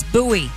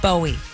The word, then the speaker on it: Bowie: And if you're local, you know how to say it. It's Bowie, not Bowie.